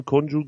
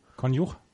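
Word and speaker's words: Konjuh: Danach gegen den Sieger von Konju gegen Liu. – Konjuch gegen Liu, ja. – Warum heißt sie Konjuch? – Weil es ein kroatischer Name ist und es nicht Konju ausgesprochen wird.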